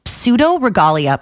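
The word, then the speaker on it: Pseudoregalia: Pseudo Regalia. Exactly the way it sounds. It's spelled. – How do you spell pseudo? – P-S-E-U.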